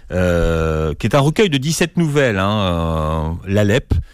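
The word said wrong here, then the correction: L'Aleph: l'Alef, voilà, je prononce mal, et à l'intérieur, il y a une nouvelle justement qui concerne Averroès, et Borges raconte les difficultés qu'Averroès a à traduire Aristote.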